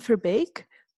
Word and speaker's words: Verbeek: It's my name, so Miriam Verbeek, but turned around.